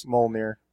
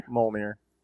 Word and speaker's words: Mjolnir: Mjolnir. – Mjolnir? – Mjolnir.